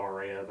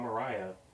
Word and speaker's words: Araya: I, all my life, have also said Tom Araya, but it's actually Tom Araya.